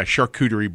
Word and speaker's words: charcuterie: Charcuterie board? Say it right? – Yeah, charcuterie board.